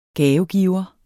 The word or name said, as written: gavegiver